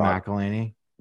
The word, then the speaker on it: McElhenney: Rob McElhenney.